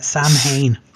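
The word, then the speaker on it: Samhain: Number two, it's it's a shame because you're going to love the name of it, NWA Seven. – Oh, that's oh beautiful name. – Yeah, and they did have it on the 28th of October. – You're pronouncing it wrong, though. It's uh, Sam Hain.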